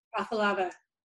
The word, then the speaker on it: Afalava: Afalava.